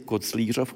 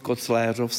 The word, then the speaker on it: Koclířov: A také jsem si všiml, že tato obec Koclérov nebo Koclířov má vlastně dvojí výslovnost. A já se zeptám pana badatele Hladkého, jak to tedy je. – Původně se to německý za rakouských dob, že jo, císařů se používalo Koclérov pro tuto oblast a dole byla, co je Fatima, neboli Koclířov, se používal název správný Kecestor Schengentau. – A v současné době se ustálila tedy výslovnost Koclérov s E.